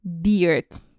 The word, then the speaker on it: beard: Запоминаем, как произносится правильно борода. Практически как пиво, только с D на конце. Beard. Beard. Никаких bird – это птичка, никаких bear – это медведь. Beard – это у нас борода.